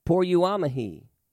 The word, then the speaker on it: poreuomai: Now, the original Greek word translated go is P-O-R-E-U-O-M-A-I, pronounced poruamahi. I think go is easier to say.